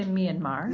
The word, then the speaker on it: Myanmar: I usually say Myanmar. – Myanmar, okay. – it's people who are from there say kind of Myanmar, so okay. They don't quite pronounce the R at the end, but that's how I pronounce it.